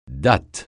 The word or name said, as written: datte